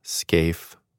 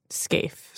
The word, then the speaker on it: Scaife: Her name is pronounced Cordelia Scaife May. – Scaife. – Scaife.